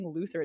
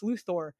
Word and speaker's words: Luthor: I love that most of Superman's villains are either aliens, mutants, or transhuman cyborgs or robots, and yet Luthor is still, is it Luthor? Or I keep saying Luthor, it's Luthor.